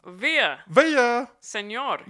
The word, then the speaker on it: Villaseñor: Okay, so you say Melissa Villa. – Señor.